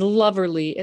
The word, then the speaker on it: loverly: And Loverly itself is very smart because it's insisting that the word lovely, which most of us would say with two syllables, is three syllables, right? We're getting that extra R in there.